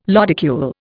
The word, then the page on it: lodicule: Indian